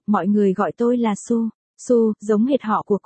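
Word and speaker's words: Su (苏): Hai, trong tiếng Trung, Tô đọc là Su, đọc lái ra là Su.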